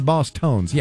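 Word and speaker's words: Bosstones: Groups like Mighty Boss Stones. I think I mispronounced it. Boss Tones, yeah.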